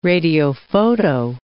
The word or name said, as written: radiophoto